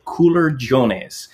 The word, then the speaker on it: culurgiones: They are called culurgiones, C-U-L-U-R-G-I-O-N-E-S, culurgiones.